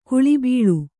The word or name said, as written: ಕುಳಿಬೀಳು